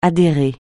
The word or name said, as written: adhérer